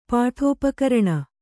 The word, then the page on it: ಪೀಠೋಪಕರಣ: pīṭhōpakaraṇa